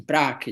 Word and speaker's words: Prague: In Prague is pronounced, not Prague.